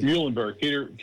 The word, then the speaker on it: Muhlenberg: Was it Peter Muhlenberg? Am I saying that correctly? – Muhlenberg.